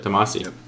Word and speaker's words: Damacy: Wadum is the new game from the Katamari, is it Damacy? I never know how to pronounce that. Let's go with it. – Damacy, yep. Yeah.